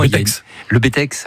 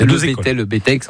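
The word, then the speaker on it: Le Bettex: Alors juste au-dessus de Saint-Gervais, où effectivement, le Bétex. – Certains disent, prononcent pas le X, le Bétex. Enfin, il y a deux écoles, mais en tout cas une seule école de ski là-bas, et c'est là-bas où je suis allé.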